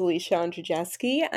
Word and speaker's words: Alicia Andrzejewski: As you pronounced beautifully, my name is Alicia Andrzejewski.